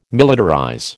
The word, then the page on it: militarize: American English